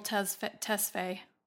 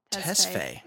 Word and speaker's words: Tesfaye: Yeah, Abel Tesfaye. – Tesfaye.